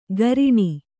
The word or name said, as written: ಕರಿಣಿ